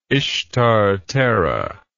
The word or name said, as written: Ishtar Terra